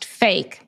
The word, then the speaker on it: PHEIC: which is actually pronounced fake, P H E I C.